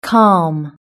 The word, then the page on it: calm: British English